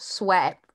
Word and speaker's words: Swett: S-W-E-T-T, but pronounced Sweat like S-W-E-A-T.